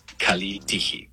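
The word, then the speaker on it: Kalitigi (καλή τύχη): En el griego moderno se dice kalitigi. Kalitigi. Pronunciación aproximada.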